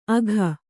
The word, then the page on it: ಅಘ: agha